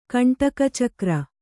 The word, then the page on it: ಕಂಟಕಚಕ್ರ: kaṇṭaka cakra